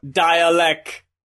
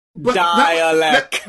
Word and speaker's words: Dälek: Oh, it's Dialect. Dialect. – That was messing me up, because I was looking at his name, and I was like, okay, they're saying dialect.